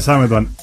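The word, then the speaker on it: Sametband: Samedwan. Mi apellido se escribe como suena. – Listo, una gran ayuda. – Sí, en inglés.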